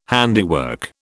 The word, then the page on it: handiwork: British English